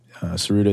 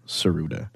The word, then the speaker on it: Tsuruda: Saruda is spelled T-S-U-R-U-D-A. Saruda.